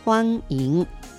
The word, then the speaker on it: 欢迎: これに相当する中国語は、欢迎光临、欢迎光临となります。漢字を説明しますね。まず最初の欢迎、これは歓迎という漢字の簡体字、中国の漢字になりますが、歓迎というふうに書いてあります。発音は欢迎、欢迎となります。